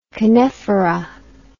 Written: canephora

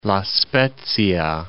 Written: La Spezia

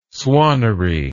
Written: swannery

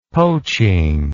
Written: poaching

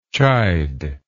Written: chidingly

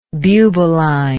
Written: bubaline

